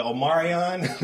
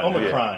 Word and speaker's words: Omicron: the Omarion? – the Ultron variant. – Yeah, you know, whatever, the Omicron. I think that's how you pronounce it. – Omicron.